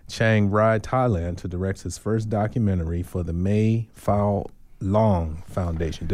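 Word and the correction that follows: Mae Fah Luang: Mayfaw Long.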